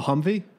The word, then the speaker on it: Humvee: Am I saying that right? – Let me see that. A Humvee? – Yeah, just as HMMW. – Yeah, a Humvee.